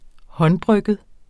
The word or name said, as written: håndbrygget